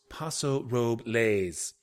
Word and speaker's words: Paso Robles: On the other hand, Tim's pronunciation of Paso Robles got me giggling. – I lived in the Central Valley for two years and can tell you it's pronounced Paso Robles.